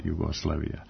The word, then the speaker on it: Jugoslavija: Ja privatno kažem televizija, Jugoslavija, međutim po normama našeg jezika i kad sam pred mikrofonom moram da kažem televizija, Jugoslavija i postoje pravila jezička kod tih složenica, kad je silazni akcenat na kraju, televizija, silazni, onda se akcenat prebacuje napred, televizija ili Jugoslavija.